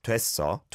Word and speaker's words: 됐어: Yeah, you can see on the 됐어, it's an or air vowel sound, so where, but it's not overly pronounced like 됐어, it's just 됐어, 됐어, 해결됐어.